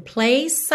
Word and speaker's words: place: Dazzling place I never knew. Place para I você junta porque o E final de place não é pronunciado, então place I never knew.